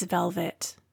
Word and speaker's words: Velvet: Hello, my name is Velvet. That is spelled V-E-L-V-E-T.